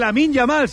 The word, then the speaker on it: Lamine Yamal: de banderas y de carteles del hermano de Lamine Yamal.